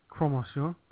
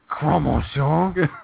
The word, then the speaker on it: Croque Monsieur: Croque Monsieur. That's how you say it. – Did you hurt yourself just now when you said that? – Croque Monsieur.